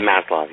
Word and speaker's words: Maslow: And did I say that right? Is that his name? – Maslow, yeah.